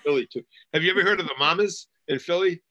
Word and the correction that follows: Mummers: Oh, the Mummers Parade. Yes, yes. Yeah, I'm not saying it right.